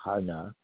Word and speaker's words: Qana: Another potential gas field, it's spelled Q-A-N-A, Hana, falls largely within Lebanese waters and would be divided between the two countries.